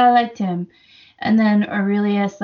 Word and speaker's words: Aurelius: And I liked Aurelius. Am I saying that right? – Yeah, Aurelius. Yeah.